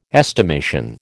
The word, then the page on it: estimation: American English